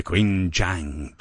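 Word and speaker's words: Qingjiang: Quinn, you're going to say it like it's racy. Let's try it again. – The Qinjiang Biota. – Yes. – Like that.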